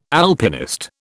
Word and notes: Alpinist: British English

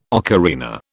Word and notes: ocarina: British English

